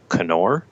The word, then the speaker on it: Knorr: as well as Knorr, or globally it's pronounced Knorr, which is something I learned when I was at the company.